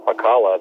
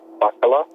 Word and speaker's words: Pakala: You just say it just like Pakala, that place over there on Kauai. I believe there's a surf spot over there called Infinity. – Well, that's how you say my name. – Do you have Hawaiian in you? – I don't. Actually, it's Finnish. – It's Finnish? – Yes, I'm 50% Finn. – Wow. And how is it pronounced in Finland? – Yeah, in Finland, they would pronounce it Bakala.